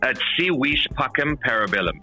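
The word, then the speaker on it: Si Vis Pacem Parabellum: At Sea Vis Pacem Parabellum.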